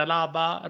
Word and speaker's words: Alaba: David Alaba, Robert Lewandowski och Mario Gomez.